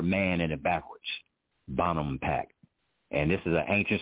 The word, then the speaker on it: Bonampak: This is a, I'm going to bring up a, a site in Mexico and it's spelled uh, B-O-N-A-M-P-A-K. That's Bonampak. Uh, B-O-N, B-O-N, Bonham, A-M, um, yeah, Bottom Pack. P-A-K, Bonampak.